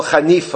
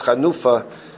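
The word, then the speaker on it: Chanufa (חנופה): And here he sticks in an Avera which people call Chanifa, although the correct pronunciation is Chanufa, unless you have a Yiddish Havara.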